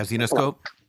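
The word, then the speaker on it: Zenescope: Zenoscope? – Yeah, Zenoscope.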